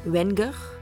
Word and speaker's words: Wenger: Ehm, gymnasiumleraar Reinier Wegne, Wenger. Zie je, daar gaan we al, hè? – Reinier... Toch in Duits Ja, hoe spreek je dat? Wenger!